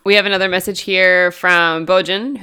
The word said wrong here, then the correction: Bojan: who we mentioned in our last episode, who is one of our major fans. So, of course... – Boyan. – Oh, is that how it's pronounced? – Yeah.